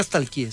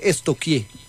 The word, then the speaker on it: stalkeé: Porque no se dice nunca stalkeé, se dice estoquié.